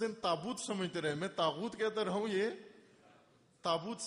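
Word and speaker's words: طاغوت: ایک مجلس میں تاغوت, 10 دن تاغوت پر مجلس پڑی, تاغوت غین کے ساتھ.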